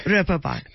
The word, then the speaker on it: Reeperbahn: I have to ask you about, how do you pronounce it, Reeperbahn? – Reeperbahn.